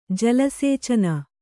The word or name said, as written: ಜಲಸೇಚನ